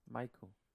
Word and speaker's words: Miku: How would you say this? M-I-K-U. – Miku? – Miku. Mikey. – No, it's Miku.